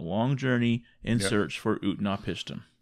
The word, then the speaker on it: Utnapishtim: Utnapishtim. You listened to this on audio. So is that how they pronounce it? – Yep. – Utnapishtim, whom they call the Faraway, or a Faraway.